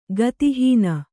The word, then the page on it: ಗತಿಹೀನೆ: gati hīne